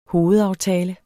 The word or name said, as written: hovedaftale